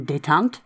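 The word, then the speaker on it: détente: So we're sort of in detente.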